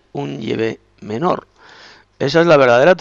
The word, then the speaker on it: Yahvé: Pues hay eruditos, estudiosos, también, que saben que la pronunciación es Yahvé, es Yahvé.